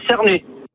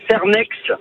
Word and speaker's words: Cernex: Alors moi j'habite à Cernay. – Voilà, je voulais savoir comment ça se prononçait. Merci. Voilà, au revoir. – Oui, ça se prononce. D'habitude, moi, je le prononçais Cernex.